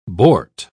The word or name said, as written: boart